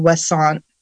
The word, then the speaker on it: Ouessant: She got really interested in this breed called Wesson, which is a French breed. It's spelled O-U-E-S-S-A-N-T.